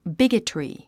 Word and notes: bigotry: bígətri; ビゲトリィ

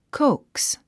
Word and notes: coax: kóuks; コウクス

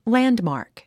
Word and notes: landmark: lǽndmàːrk; ランドマーク

